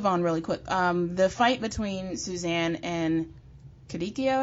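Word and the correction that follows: Kukudio: I think is pronounced. What was your takeaway? – Kakudio. Like Susudio? – Susudio? – That's right. Just like they made that reference, yeah.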